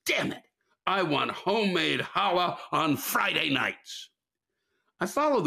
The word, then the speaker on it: challah: I've been baking challah for years now, or as some Jews might pronounce it, challah. It's actually holla.